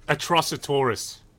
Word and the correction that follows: Atrocitus: Atrocitus. – Wow. – Yeah. – It looks good too. – Yeah. Yeah, he was cool. – Good old Atrocitus.